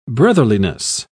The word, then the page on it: brotherliness: British English